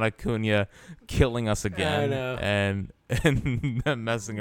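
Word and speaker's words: Acuña: And he's like, learn how to say his name. It's Acuna.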